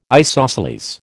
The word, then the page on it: isosceles: American English